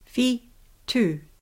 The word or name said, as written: whitu